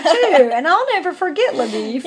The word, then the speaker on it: LaBoeuf: And by the way, you know, in the book, his name is spelled like L-A-B-O-E-U-F or something like that. – Yeah, it sounds French to me. – It sounds French. And it looks like it would be pronounced LaBeouf or something. Mm-hmm. But I did read one commentary, and they said they think that they mispronounced his name as LaBeef, kind of as a poke of, to poke fun at him because he was from Texas.